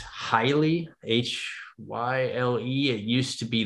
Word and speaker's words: Hyle: I think it's pronounced Hyle, but it's the H-Y-L-E.